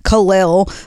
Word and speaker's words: Khalil: In the past, we did mispronounce Khalil Roundtree. I just learned it's Khalil.